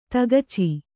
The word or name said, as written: ತಗಚೆ